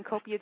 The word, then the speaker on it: Kopia: Just type in Copia Jackson. My name is spelled K-O-P-I-A, then Jackson.